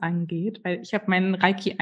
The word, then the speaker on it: Reiki: Ah, aber also ich nach japanischer Aussprache. – Hm. – Reiki.